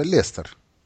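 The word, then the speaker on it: Leicester: Там не читается и кратко, это просто Лестер.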